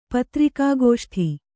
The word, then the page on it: ಪತ್ರಿಕಾಗೋಷ್ಠಿ: patrikā gōṣṭhi